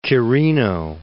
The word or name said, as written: Quirino